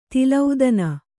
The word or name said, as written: ತಲೆದೊನೆ